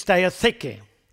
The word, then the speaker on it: diatheke: And then in the Greek, it's diatheke. Diatheke. That would be the Greek.